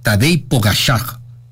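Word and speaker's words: Tadej Pogačar: Dice, se pronuncia Tadej Pogacar en vez de Tadej Pogacar. Tadej Pogacar. Pogacar, no sé.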